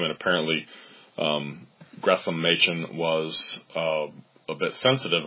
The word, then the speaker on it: Gresham: And the thing is, I had heard one other person pronounce it as an S instead of an SH, and I believe that was Martin Lloyd-Jones in a sermon. He mentioned Gresham Machen, and I thought, I wonder if he's mispronouncing it, because it's S-H-A-M, his middle name.